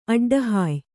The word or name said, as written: ಅಡ್ಡಹಾಯ್